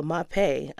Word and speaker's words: Mapei: Mapei, is that how you pronounce it? – I think so. Mapei, M-A-P-E-I.